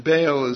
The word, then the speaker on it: Baal: And Baal is pronounced Baal in Hebrew.